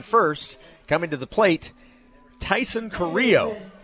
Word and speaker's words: Taison: T A I S O N, pronounced Tyson.